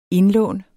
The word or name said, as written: indlån